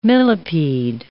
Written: millipede

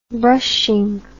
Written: brushing

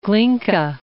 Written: Glinka

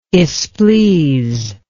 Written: esplees

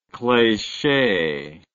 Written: cleche

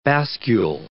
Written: bascule

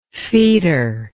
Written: foetor

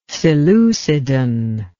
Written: seleucidan

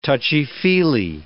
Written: touchy-feely

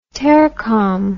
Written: tercom

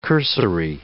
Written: cursory

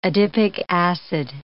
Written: adipic acid

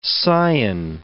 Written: scion

